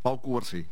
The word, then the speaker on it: Pau Cubarsí: Pau Cubarsí.